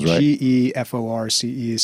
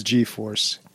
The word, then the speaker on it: GeForce: Well, it's spelled G-E-F-O-R-C-E. – So, yeah, I must have pronounced it G-E-Force, but it is G-Force.